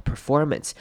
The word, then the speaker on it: performance: Performance 这个字要注意的是后面的 a n c e 会念成 ance，所以会念成 performance。 Performance 也就是先念一个 men，再念 s 的声音。